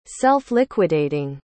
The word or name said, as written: self-liquidating